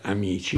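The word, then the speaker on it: Ameche: si scriveva Meche, ma si diceva Amici, perché appunto si chiamava Amici, era il suo cognome,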